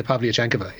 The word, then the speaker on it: Pavlyuchenkova: Anastasia Pavlyuchenkova. Yeah. – There you go. It's the even. There's an extra syllable at the end of that there, and he just like, you know, what a pro, just straight in there, not even a blink.